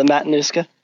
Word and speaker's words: Matanuska: I believe, am I saying it right? Matanuska Glacier? – Yeah, the Matanuska.